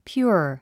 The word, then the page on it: pure: pjúə r; ピュア